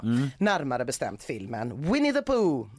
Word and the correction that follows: Winnie the Pooh: eller för, förlåt, så här uttalas den, Winnie the Pooh, Blood and Honey.